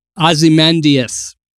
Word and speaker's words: Ozymandias: Yeah, Ozymandias.